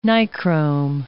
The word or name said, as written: Nichrome